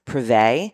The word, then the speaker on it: Privett: I think the way you say her last name is Preve, but it's spelled P-R-I-V-E-T-T.